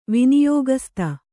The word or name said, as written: ವಿನಿಯೋಗಸ್ತ